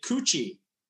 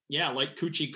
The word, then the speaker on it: Koetje: So we pronounce it Koetje. Like Koetje, Koetje, Koo, I guess. Like you'd say to a little toddler or something.